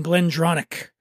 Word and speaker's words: Glendronach: And she currently oversees the whiskey-making operations for 3 iconic Scotch brands, Benriach, Glenglassaugh, and Glendronach. Try pronouncing that 3 times fast when you've had a little whiskey. Benriach, Glenglassaugh, and Glendronach. That's how you say it.